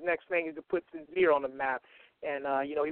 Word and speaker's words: Synzere: And the other mixtape you should definitely check out is from Sinzeer, and that's spelled S-Y-N-Z-E-R-E.